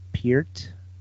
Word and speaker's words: Peart: that one won't happen because Neil Pert is uh, Neil Peart. P E Peart Neil. Is that how you pronounce it? Yeah, it's E with a P and a T.